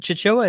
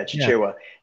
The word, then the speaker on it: Chichewa: Is it Chichewa? Am I saying it right? – Uh, yeah, yeah, you are. Yeah, Chichewa.